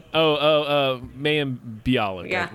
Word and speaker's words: Mayim Bialik: Like, they had to get Malin Blahnik? I'm butchering her name. – Mayim Bialik, whatever.